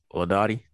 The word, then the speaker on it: Laudati: I'm your host, and I'm here with Joe Laudati.